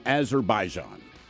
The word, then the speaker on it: Azerbaijan: And today we toast an unidentified 36-year-old man from Azerbaijan.